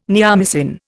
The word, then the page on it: neomycin: Indian